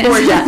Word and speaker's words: Borgia: It is Borgia.